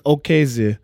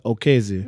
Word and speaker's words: Okezie: Like, it's Okezie. And it sounds like a R, but it's not. It's O-K-E-Z-I-E. but it sounds like okeze. And it sounds like an R when you speaking in dialect, but it just means swift.